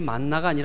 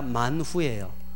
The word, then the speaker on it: 만후: 원래는 이 발음이 만나가 아니라 만후예요. 만후.